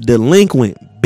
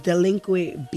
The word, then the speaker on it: DLEQUINT: But before you go, man, I need you to look into your camera and I need you to say, it's pronounced delinquent, bitch. – Yeah, just like that. – It's pronounced delinquent, bitch.